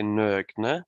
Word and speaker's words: Nogne: How should I pronounce the name of the brewery? – Traveling around the world, I've heard it pronounced so many different ways, but in Norwegian we say Nogne.